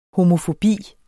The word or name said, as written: homofobi